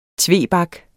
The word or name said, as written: TVEBAKS